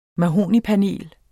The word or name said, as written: mahognipanel